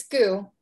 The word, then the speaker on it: Goux: It's Goux, but you're close.